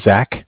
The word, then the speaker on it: Zach: So my name is Zach, spelled Z-A-C-H, with Bank of Cardiff. Cardiff is spelled C-A-R, car, D-I-F-F, like different.